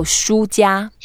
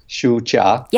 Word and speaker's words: 输家: Yeah, so 输家. – 输家. – Yeah, 输 is the first tone, and that just means you lost something. And it's also the same pronunciation as books in Chinese.